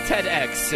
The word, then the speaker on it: TEDx: By the way, it's TEDx is what we learned.